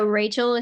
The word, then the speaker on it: Rachael: So Rachel is spelled like Michael, R-A-C-H-A-E-L, and then Lee is L-E-I-G-H.